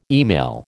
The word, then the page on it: email: American English